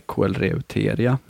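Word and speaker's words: Koelreuteria: Kinesträdet var alltså eh, otroligt svårt att stava till vad det heter på latin, men jag uttalar det i alla fall som Kelrevtria paniculata. Eh, vissa säger Coelreuteria, eh, mer eh, som det stavas.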